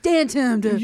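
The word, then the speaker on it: DanTDM: Oh, it's Dantum. – You know it's Dantum.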